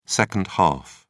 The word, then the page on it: second half: British English